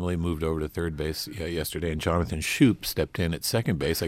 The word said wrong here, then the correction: Schoop: Yeah, that's a difficult name to pronounce. It's Scope. Ah, Jonathan Scope. – That's right. – But that's a very tough one. – I forgot, but when you look at it, because it's spelled S-C-H-O-O-P.